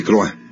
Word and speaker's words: Croix: el virrey Marqués de Croa, se escribe Croix,